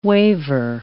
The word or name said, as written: waver